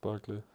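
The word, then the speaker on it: Barkley: Doch, der heißt Barclay. – Heißt der wie die Bank.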